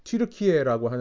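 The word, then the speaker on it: Türkiye: 성령께서 이렇게 소아시아 진역, 지금 이제 뭐 터키, 뭐 요즘은 이제 한국에서는 터키라고 안 그러고 튀르키예라고 하는데요. 원래 발음대로요.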